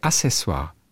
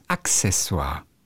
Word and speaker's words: Accessoire: Ich sage Accessoire. Ist falsch. Es heißt Accessoire.